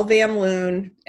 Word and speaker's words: Van Loon: My name's Michelle Van Loon, and it's spelled just like it sounds, V-A-N-L-O-O-N.